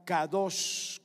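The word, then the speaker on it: kadosh (קדוש): Pero la palabra santidad es la palabra kadosh con K. En el diccionario se escribe kodesh, pero la pronunciación es así.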